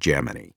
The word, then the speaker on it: Gemini: So for this, I decided to respect NASA tradition, and throughout, I use the pronunciation Gemini.